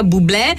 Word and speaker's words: Bublé: Con la E aperta, Bublé, che è canadese, non so se lo sapevate, come anche Céline Dion, lei invece è alla francese, la pronuncia.